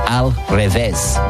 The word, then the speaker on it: al revés: Al revés. R-E-V-E, accent S. Al revés.